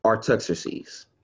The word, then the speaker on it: Artaxerxes: Artaxerxes. Okay? Artaxerxes. Now, 5 of them are books of law written by Moses. 13 of them are covered by from the time to Moses to the king Artaxerxes.